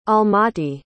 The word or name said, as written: almaty